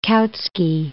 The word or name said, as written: Kautsky